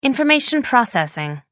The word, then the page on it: information processing: American English